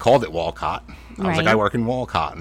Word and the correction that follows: Wolcott: And they're like, who the fuck is Wolcott? They said, Wilkit. Wilkit. I was like, Wilkit. That's not Wilkit. Wilkit would be W-I-L-K-E-T or something like that.